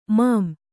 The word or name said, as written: ಮಾಂ